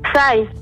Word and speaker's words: Psy: Sai.